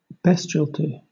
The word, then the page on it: bestiality: British English